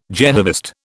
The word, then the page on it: Jehovist: British English